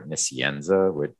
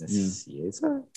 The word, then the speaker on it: Nicieza: I think there's an N in there, Nicienza, but it's not. Yeah. It's Nicieza.